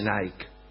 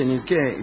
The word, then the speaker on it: Niké: Niké quiere decir victoria, no se dice Nike, se dice Niké, y por eso se llaman así las zapatillas.